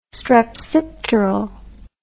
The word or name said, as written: strepsipteral